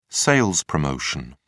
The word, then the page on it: sales promotion: British English